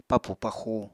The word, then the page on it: papù paxu: Apatani